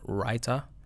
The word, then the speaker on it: raita: called raita, R-A-I-T-A, I believe it's spelled, raita.